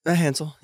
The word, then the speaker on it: Matt Hansell: Matt Hansell.